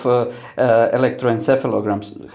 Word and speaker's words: electroencephalograms: But since the principles of electroencephalograms, have I said it right? – Yeah, okay.